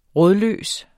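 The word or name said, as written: RÅDLØST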